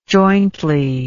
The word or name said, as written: jointly